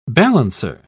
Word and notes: balancer: British English

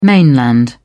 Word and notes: mainland: British English

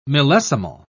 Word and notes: millesimal: British English

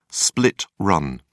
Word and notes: split run: British English

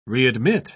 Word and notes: readmit: British English

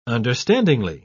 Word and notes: understandingly: British English

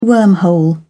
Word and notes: wormhole: British English

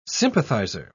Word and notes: sympathiser: British English

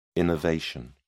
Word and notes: innervation: British English

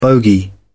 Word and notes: bogie: British English

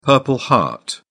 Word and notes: purple heart: British English

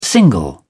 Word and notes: single: British English

